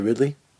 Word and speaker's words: Ridley: Daisy Ridley? Yeah, that's how you say her name.